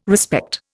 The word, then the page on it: respect: Indian